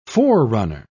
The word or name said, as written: forerunner